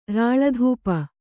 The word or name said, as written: ರಾಳಧೂಪ